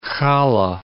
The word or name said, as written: challah